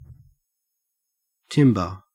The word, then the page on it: timbre: Australian English